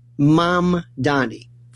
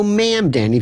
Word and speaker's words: Mamdani: Three syllables. Mom, like mom and dad. Donnie, like Donny Osmond. Mamdani. Simple. Mamdani. You might even throw ma'am, Danny, if you want to go more upstate, which is fine, too.